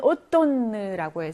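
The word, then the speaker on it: automne: 4번에 오톤느라고 해서 가을이라는 건데 요거는 M이 발음이 안 나고 N이 발음이 나는 거죠. 오톤느, 느 발음 나고 있죠.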